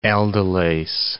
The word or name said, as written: aldolase